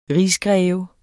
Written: rigsgreve

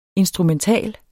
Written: instrumental

